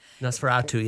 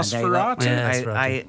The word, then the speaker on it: Nosferatu: Both on this one, actually, because it's a cinema offering and it's called Nosferatu. – Nosferatu, yes. – Yeah. Nosferatu.